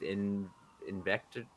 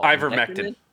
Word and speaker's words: ivermectin: And yeah, and they're asking if he's been treating this with Invector. I can't even remember what it's called. – Ivermectin.